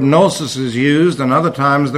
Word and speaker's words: gnosis: Gnosis is the way it's pronounced in Greek. Notice the G is in there.